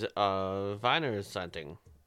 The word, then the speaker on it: Wiener Zeitung: W-I-E-N-E-R, Wiener. Zeitig, Z-E-I-T-U-N-G. – Z-E-I-T-U-N-G. Oh, that is Wiener Zeitung.